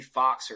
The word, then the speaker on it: Fuchs: My Instagram is J-F-U-C-H-S-91, so J Fox or J, it's spelled, looks like Fuchs.